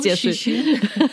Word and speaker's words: Cixin: Liu Shixin. Liu Shixin ist ein. Also wir hoffen, dass wir es richtig ausgesprochen haben, aber ich habe extra meinen Chine oder einen meiner chinesischen Kollegen gefragt. Genau. Und der hat uns das so gesagt.